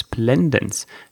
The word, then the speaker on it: splendens: Richtig heißt es Splendens.